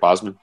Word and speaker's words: Boseman: Yeah, the thing I appreciate about the acting job in this film, and I was upset Chadwick Boseman. Did I say that right? Boseman.